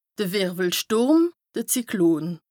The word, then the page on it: de Wìrwwelstùrm, de Zyklon: Bas-Rhin; Strasbourg